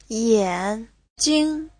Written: yan3jing5

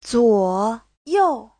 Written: zuo3you4

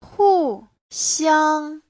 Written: hu4xiang1

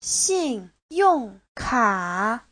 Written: xin4yong4ka3

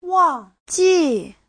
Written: wang4ji4

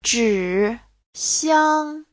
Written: zhi3xiang1

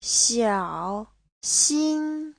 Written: xiao3xin1

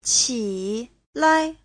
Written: qi3lai5

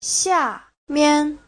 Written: xia4mian5

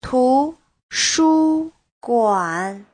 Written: tu2shu1guan3

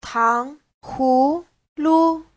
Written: tang2hu2lu5

